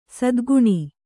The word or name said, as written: ಸದ್ಗುಣಿ